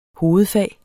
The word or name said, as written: hovedfag